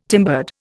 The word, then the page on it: timbered: Indian